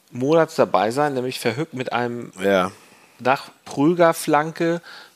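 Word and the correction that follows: Verhoek: Der heißt, er, heißt, er wird übrigens Verhook ausgesprochen.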